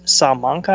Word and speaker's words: Salamanca: Yeah, it's south of Buffalo, but yeah, it's Salamanca, I think is how you say it.